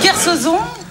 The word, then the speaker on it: Kersauzon: Claire Chauchon, c'est dur. – Kersauzon. Ah bah Kersauzon, ouais. Kersauzon, Kersauzon, Olivier de Kersauzon.